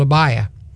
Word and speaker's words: Abiah: In the New Testament, that Greek word, it's the way they pronounce it, it's called Abiah.